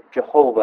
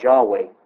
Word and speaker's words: Yahweh: Now, if we focus back on the memorial name, the name Yahweh has been mistakenly translated Jehovah in other locations, but the correct pronunciation is Yahweh.